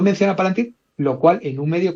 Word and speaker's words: Palantir: se pronuncia Palantir, eso, Víctor.